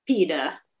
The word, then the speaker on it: pide: Pita. Very delicious. – I've spelt it wrong like 6 times. So P-I-D-E. – That's right. – But pronounced pita. Pita.